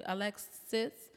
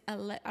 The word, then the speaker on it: Alexa: She told me at my brother's house, I pronounced her name Alexis, and she said, my name is Alexa.